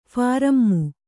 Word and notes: ಫಾರಮ್ಮು: prārammu

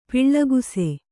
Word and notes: ಪೊಳಕಿಸು: poḷakisu

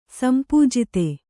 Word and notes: ಸಂಪೂಜಿತೆ: sampūjite

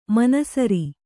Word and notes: ಮನಸರಿ: kannada

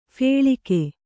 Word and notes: ಫಲ್ಗು: kannada

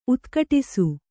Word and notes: ಉದ್ಘಟಿಸು: udghaṭisu

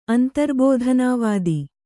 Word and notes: ಅಂತರ್ಬೋಧನಾವಾದ: antarbōdhanā vāda

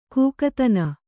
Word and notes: ಕಾಕತನ: kākatana

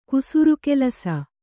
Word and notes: ಕುಸುರುಕೆಲಸ: kusuru kelasa

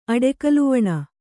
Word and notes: ಅಡೆಕಲುವಣ: kannada

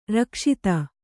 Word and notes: ರಕ್ಷಿತ: rakṣita

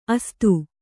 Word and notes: ಅಸಾಧು: Kannada

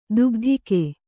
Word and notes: ದುಗ್ಧಿಕೆ: dugdhike

